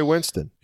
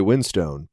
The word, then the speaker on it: Winstone: There's an E at the end of it, so I always just said Ray Winstone.